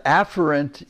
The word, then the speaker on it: afferent: By the way, I looked it up on Webster where they pronounce the words for you, and the afferent is the proper pronunciation. Efferent has two pronunciations, but in both of them, the focus should be on the first syllable.